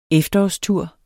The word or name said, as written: efterårstur